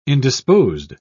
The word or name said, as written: indisposed